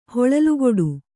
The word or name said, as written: ಹೊಳಲುಕೊಡು